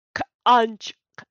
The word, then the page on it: Xantcha: Phyrexian